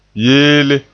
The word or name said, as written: yeele